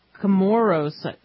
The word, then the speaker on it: Comoros: And so they recovered some bodies who were aboard this Yemeni Airways that crashed off the coast of Comoros, is how you say it, in the Indian Ocean.